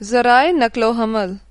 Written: ज़राए’नक़्लोहमल

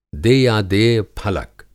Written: देयादेय-फलक

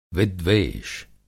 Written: विद्वेष

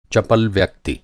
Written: चपल

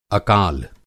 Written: अकाल

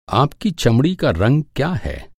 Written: चमड़ी

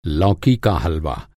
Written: हलवा

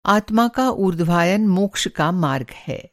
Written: ऊर्ध्वायन

